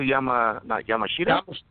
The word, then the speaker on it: Yamashita: How do I pronounce it? Yamashita? – Yep.